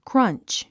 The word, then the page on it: crunch: krʌ́ntʃ; クラァンチ